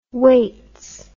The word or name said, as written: weights